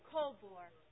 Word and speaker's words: Kobor: Diane Colbor. – Diane Colbor? – Colbor. – How do you spell that? – K-O-B-O-R.